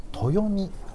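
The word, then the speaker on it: 豊海: 豊見お魚ミュージアムってのは知ってます。豊かな海と書いて豊見お魚ミュージアム、ま、豊見が分かんないですね。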